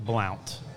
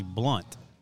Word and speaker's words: Blount: Well, you know, it looks like Blount. It's actually Blunt.